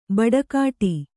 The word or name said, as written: ಬಟ್ಟೆಗೆಡು